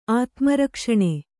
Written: ಆತ್ಮರಕ್ಷಣೆ

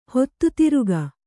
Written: ಹೊತ್ತುತಿರುಗ

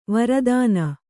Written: ವೀರತನ

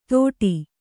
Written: ತೋಡಿ

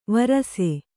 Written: ವಾರಸು

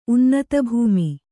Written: ಉನ್ನತಭೂಮಿ

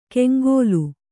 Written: ಕೆಂಗೋಲು

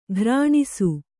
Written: ಖುರಣಸ

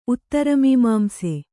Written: ಉತ್ತರಮೀಮಾಂಸೆ

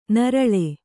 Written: ನೀರೆಳ್ಳು

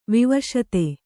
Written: ವಿವಶತೆ

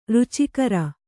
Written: ರುಚಿಕಾರ

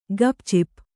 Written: ಗಪ್‍ಚಿಪ್

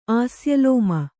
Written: ಆಸ್ಯಲೋಮ

